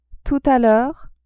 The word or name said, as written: tout a l'heure